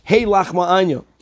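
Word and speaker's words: Hey Lachma Anya (הא לחמא עניא): So to be consistent with the words that we find in the Chumash, indeed it should be pronounced with a tzedei, Hey Lachma Anya.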